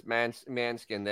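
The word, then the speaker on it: Måneskin: Manoskin. – No, okay. In Italian, it's I listened to Italian radio. It's Manskin. That's the way they say it in Italy.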